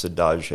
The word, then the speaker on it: saudade: S-A-U-D-A-D-E. – Yeah, and I think it's pronounced sadage. It's Portuguese.